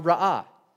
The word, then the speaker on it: ra'ah: Both of these words, when spoken out loud, are ra'ah.